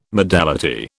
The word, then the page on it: modality: British English